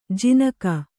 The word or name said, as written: ಜನಿಕ